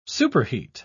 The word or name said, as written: superheat